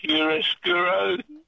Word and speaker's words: chiaroscuro: It kind of starts that way a little bit, but it's an actual Italian word, so it's four syllables long. – Chiaroscuro.